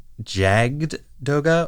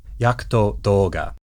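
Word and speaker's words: Jagd Doga: The mobile suit, which is initially piloted by Gune and is spelled J-A-G-D space D-O-G-A. You might think that that is supposed to be Jagged Doga or Yagged Doga. In the Japanese, it's Yakuto, Yakuto Doga. Now, initially, I thought that the J-A-G-D part of the name came from the German word for hunter, or Jäger.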